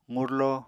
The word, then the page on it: qurlo: apatani